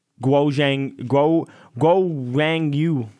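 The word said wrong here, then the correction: Zhou Guanyu: I can't say his name, but Zhou, you know what I mean. – Yeah, I know what you mean. – Zhou Guang Yu.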